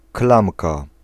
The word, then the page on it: klamka: /ˈklamka/